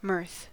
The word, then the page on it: mirth: American English